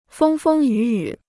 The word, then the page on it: 风风雨雨: fēng fēng yǔ yǔ